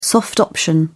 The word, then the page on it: soft option: British English